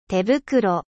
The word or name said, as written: gant